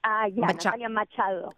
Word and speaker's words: Natalia Machado: Natalia Machado.